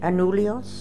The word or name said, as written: Anulios